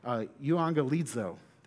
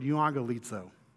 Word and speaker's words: euangelizo: Now, the word preached here is actually the Greek word, how do I say it? Well, euangelizo. There it is. The Greek word euangelizo.